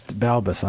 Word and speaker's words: Balbes: Balbes.